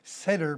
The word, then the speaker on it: Seder: One of them is the Seder plate, S-E-D-E-R, or Seder sometimes it's pronounced, for Passover.